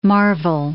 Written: Marvell

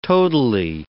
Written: totally

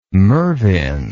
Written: Mervin

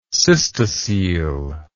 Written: cystocele

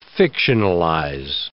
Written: fictionalize

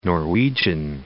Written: Norwegian Sea

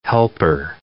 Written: helper

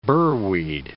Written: burweed

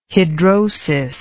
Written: hidrosis

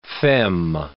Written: femme

